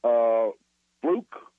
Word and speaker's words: Fluke: They had Sandra, and I'm going to spell her name, F-L-U-C-K, Fluke, very good.